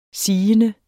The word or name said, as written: sigende